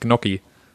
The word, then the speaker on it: Gnocchi: Wie spricht man die aus? – Nach meinem Kenntnisstand sind das Gnocchi.